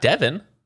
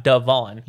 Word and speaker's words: Devon: Because how would you say this name? D-E-V-O-N. – Devon. – That's only because you know. Is it? Would you say Devon? – Yeah. – D-E-V-O-N? – Not Devin. – They love to say Devin.